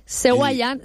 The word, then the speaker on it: Seguayán: Seguayán, Segua es fonético, se escribe Segua, pero es Seguayán, es caminar del tigre.